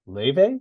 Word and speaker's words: Laufey: So this is the second album by a singer named Laufey. Is how she pronounces it, correct? – I believe it is, even though it is spelled Laufey.